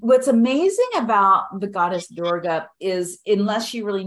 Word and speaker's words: Durga: the image of Durga. I think that's how you pronounce it. Is that right? – Yeah, Durga. Yeah.